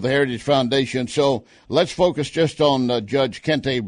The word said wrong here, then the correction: Ketanji: Brown. Maybe I'm mispronouncing. I'm not trying to. – Ketanji. Yeah, Ketanji Brown Jackson.